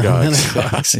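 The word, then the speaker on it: emmenagogues: Amenagogues.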